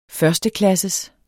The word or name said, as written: førsteklasses